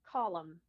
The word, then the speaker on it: Colm: but Colm or Colm? – C-O-L-M. – Say it again. – Colm.